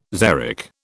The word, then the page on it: xeric: British English